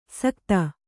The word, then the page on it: ಸಕ್ತ: sakta